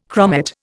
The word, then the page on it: chromate: Indian